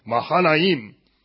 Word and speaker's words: Mahanaim: and brought him over to Mahanaim." Try that word, Mahanaim. Oh, good, but now say it like Hebrew. Hebrew's like Mahanaim.